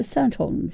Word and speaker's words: Staunton: Staunton, no sé si lo pronuncio bien. Staunton.